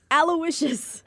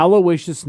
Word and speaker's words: Aloysius: Aloysius. – Wow. – I think that's how you say it. – Yes, it is. Yeah. Aloysius Snuffleupagus.